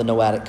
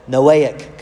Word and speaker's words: Noahic: I keep throwing a T in there, an extra syllable. Noahic covenant.